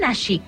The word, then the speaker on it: Nashik: Nació en Nashik, ¿lo digo bien? – Sí, nací.